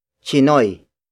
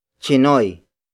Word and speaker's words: Tsinoy: Collectively, they are called Filipino Chinese or Chinoy. Chinoy. Chinoy. Which is spelled as T-S-I-N-O-Y. The next word is sha. Sha. Sha. Sha is spelled as S-I-Y-A.